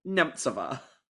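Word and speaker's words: Němcová: But all over the show, she's just Petra Nemcova. – Yeah. – I practiced so hard to say Nemtsova.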